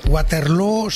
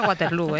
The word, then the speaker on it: Waterloo: Pues no lo es, desde luego, pero ABBA ha hecho mucho daño en la pronunciación de Waterloo, que se pronuncia igual que Feijóo.